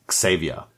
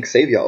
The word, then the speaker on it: Xavier: Und einer der Leute, die da beisitzen, ist Charles Xavier dass ich den Xavier ausspreche, ist übrigens so eine Kompromisslösung. In den Filmen sagen sie immer Xavier, was irgendwie komisch ist, weil so schreibt er sich nicht. Ich hatte ihn vorher immer als Xavier ausgesprochen, so wie der äh, rechtsradikale äh, Heißburger Sänger. Und ähm, vor allen Dingen ist das halt ein Homophon zu Retter, also Savior.